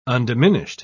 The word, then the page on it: undiminished: British English